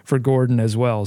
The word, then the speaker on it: Gordon: Yeah, Gordon is how you pronounce that last name.